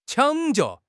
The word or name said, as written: 抢救